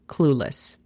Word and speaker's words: clueless: La pronunciación fonética sería C-L-U-L-E-S. Clueless. Number two. Número dos. Happy. Happy significa feliz. La pronunciación fonética sería J-A-P-I.